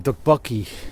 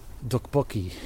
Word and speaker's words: tteokbokki: Dukbokki. The way it's spelled, it says... It's like T-T-E-O... K-E-O-E-I-U. I don't know how to spell this thing. But I remember super researching it. It's dukbokki. So when I ordered it, I'm like, hey, can I have the dukbokki?